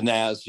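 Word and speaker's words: Nazz: Is it Nas or Naz? How do you pronounce it? – The Naz, yeah.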